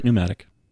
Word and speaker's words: pneumatic: I might be saying that wrong. Yeah, that's right, pneumatic.